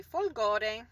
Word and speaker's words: Folgore: Folgore.